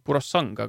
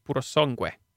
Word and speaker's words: Purosangue: tahaks öelda , et see , noh , võiks arvata , et selle nimi on Purosang , aga tegelikult see hääldatakse itaaliapäraselt Purosangue .